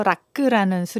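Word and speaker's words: rakı: 터키에서 락크라는 술이 있거든요. – 아, 예, 스펠링은 R-A-K-I 똑같지만 네네네. 그 I자가 점이 없는 I라서 끄로 발음해야 돼요.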